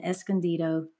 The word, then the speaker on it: Escondido: So along with her husband, Phil, Elyse attends Grace Bible Church in Escondido, California. Did I pronounce that right, Elyse? – Yes, you did.